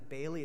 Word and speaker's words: Bayly: And if you don't know, my last name is Bailey. It's spelled B-A-Y-L-Y.